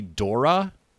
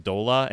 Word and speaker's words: Dora: I believe that her name is actually Dora, which makes sense. Like, that's an actual name that we've heard of, Dora. Mm-hmm. But the RL confusion in the Japanese pronunciation has led to her just being referred to as Dola, and that's how she is referred to in the dub.